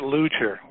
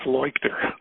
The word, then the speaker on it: Leuchter: He pronounces it Luchter, except that Luchter. He's in Germany and then it's Leuchter.